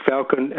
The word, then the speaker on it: Falcon: And the thing about that is that the A in Falcon and the E in Melbourne are pronounced exactly the same way. So Falcon and Melbourne. – Falcon, oh yeah, it's rhyming slang. – It's in, well, it's in between, the vowel sound is in between an A and an E.